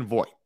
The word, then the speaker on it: Voit: It rhymes with Croit. His name is Luke motherfucking Voit.